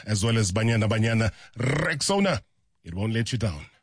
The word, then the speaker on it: Rexona: A brand that you've trusted for years and years and years is stepping forward with their bold new look, and it's pronounced Rexona.